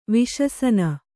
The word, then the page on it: ವಿಶಸನ: viśasana; kannada